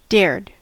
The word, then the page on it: dared: American English